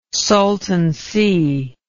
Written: Salton Sea

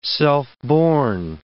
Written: self-born

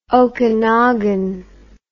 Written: okinagan

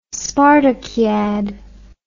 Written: spartakiad

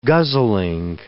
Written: guzzling

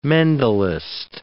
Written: Mendelist

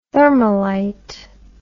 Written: thermalite

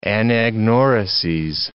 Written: anagnorises

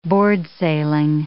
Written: boardsailing

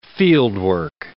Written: fieldwork

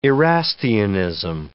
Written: Erastianism

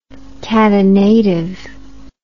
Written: catenative

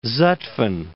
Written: Zutphen

